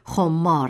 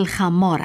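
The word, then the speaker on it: خمّار: شاید شما تصور کنید که مولانا کلمه خمار را به ضرورت وزن شعر به صورت خمار آورده است و شاید شما به همین دلیل آن را خمار تلفظ کنید، ولی این تصور درست نیست. این کلمه در اصل خمار است و مانند قصاب، نجار و دلاک یک صفت شغلی است به معنی می فروش.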